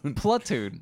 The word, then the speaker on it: Platoon: like what you did for Plantoon, where everyone was like, John Wayne. – Plantoon? – Is that how you say it? – No. – How do you Platoon.